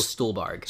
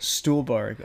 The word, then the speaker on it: Stuhlbarg: Michael Stuhlbarg. – Stuhlbarg.